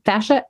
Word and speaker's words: fascia: Fascia, F-A-S-C-I-A.